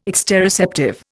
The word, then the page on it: exteroceptive: Indian